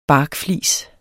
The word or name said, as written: barkflis